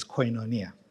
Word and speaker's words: koinonia: He teaches that the Greek word for communion here is koinonia, and in my South African dialect, koinonia. I prefer koinonia, but I believe the right pronunciation is koinonia.